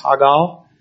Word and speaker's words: hagah: It's called Hagal, H-A-G-A-H. It's pronounced Hagal.